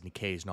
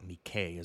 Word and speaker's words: Nicaise: And yes, it is Nicaise, not Nikkei, as I pronounced it last week.